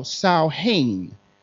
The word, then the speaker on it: Samhain: It's spelled Samhain, but pronounced Sow, like cow, Samhain.